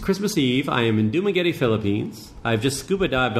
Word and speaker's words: Dumaguete: Dumaguete, that's right. – Did I say that right? Dumaguete? – Yes, there's an accent, so I may even say it wrong.